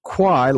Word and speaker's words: Quy: I got locked in on Key. I apologize because I was thinking that was the phonetic pronoun. Her last name is spelled Q-U-Y, and it's pronounced Kwai, like the River Kwai, Bridge on the River Kwai.